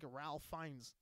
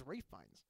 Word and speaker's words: Ralph Fiennes: Man, I got guys who still say things like Ralph Fiennes. It's like it's that's not his name, but they'll still say it. – How do you pronounce it? – It's Ralph Fiennes.